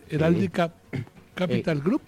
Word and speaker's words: Heraldica Capital Group: ¿Sí lo dije bien esta vez? Heraldica Capital Group. – Sí, correcto. Muy bien, muy bien.